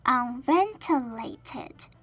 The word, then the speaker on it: unventilated: Unventilated 这个单字很长，但是我们如果分音节念就会比较容易。那我们分为五个音节 Unventilated，重音放在 ven 上面。那 ven 后面的 ti 的 i 念作嘴巴微张的的音，整个单字再念作一次，念成 Unventilated。